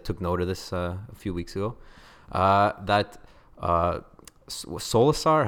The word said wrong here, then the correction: Solskjaer: how do you pronounce the manager of Solskjaer. Okay. – There you go. Boom. Nailed it.